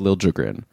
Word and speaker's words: Liljegren: Then Henry Thrun and Vincent Desharnais, and then Marc-Edouard Vlasic, who's somehow still in the league, and Timothy Liljegren, or Liljegren. I think he did come out and say like he wants it to be pronounced Liljegren.